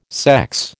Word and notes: sex: American English

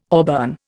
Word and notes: auburn: Indian

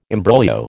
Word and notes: imbroglio: American English

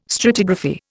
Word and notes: stratigraphy: Indian

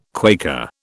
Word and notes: Quaker: British English

